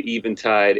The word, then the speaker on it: Eventide: It is a tribute to the Eventide H9. – All right. That's a correct pronunciation, Eventide. I'm like, is it a vent? Is it tide?